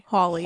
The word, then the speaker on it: Hawley: H-A-W-L-E-Y. – Hawley.